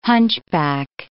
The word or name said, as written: hunchback